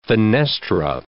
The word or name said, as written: fenestra